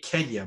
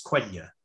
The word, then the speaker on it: Quenya: So, anyways, yeah, Kenya is Quenya. I read it Kenya, but it's Quenya.